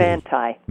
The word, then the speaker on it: Manti: Is it Monti? Did I say it right? Or Manti? – Manti.